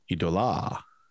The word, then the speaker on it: Eidola: E-I-D-O-L-A. – Depends what emphasis you put on the syllable. – Yeah. Idola.